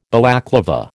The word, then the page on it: Balaclava: American English